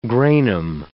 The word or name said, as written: granum